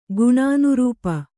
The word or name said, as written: ಗುಣಾನುರೂಪ